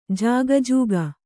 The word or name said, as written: ಜಿಕಿಜಿಕಿ